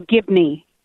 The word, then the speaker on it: Gibney: It's actually Shannon Gibney, but yeah, thank you. – How do you say your last name, Gidley? – No, Gibney, G-I-B-N-E-Y.